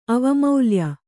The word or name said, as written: ಅವಮೌಲ್ಯ